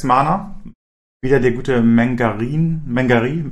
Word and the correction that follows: Menagerie: wie wird das ausgesprochen? Mengarin, Wächterin? – Menagerie, ich habe mich für uns gesagt. – Ja, Menagerie, Wächterin.